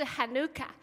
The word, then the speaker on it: Hanukkah: 但是他们全部，你不要读 Hanukkah 哦，他们全部都是一个读音，就是 Hanukkah。